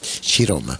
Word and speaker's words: Ciroma: Se escribe Chiroma, C-I-R-O-M-A, pero en italiano es Chiroma.